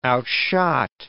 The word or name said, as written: outshot